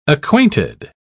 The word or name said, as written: acquainted